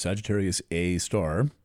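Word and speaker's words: Sagittarius A*: The core of our galaxy is in Sagittarius, and that means that the supermassive black hole called Sagittarius A star, written Sagittarius capital A, with an asterisk next to it, pronounced Sagittarius A star, is at about 27 degrees of Sagittarius.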